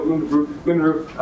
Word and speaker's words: Unruh: Now, one thing we can find from a semi-classical theory is that Unruh, I pronounced it correct. No, Unruh, I'm so sorry.